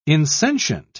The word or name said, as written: insentient